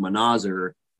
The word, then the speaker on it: Manazir: Well, this one instructor thought that my last name, which is pronounced Manazir, you know, it was kind of funny.